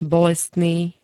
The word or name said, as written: bolestný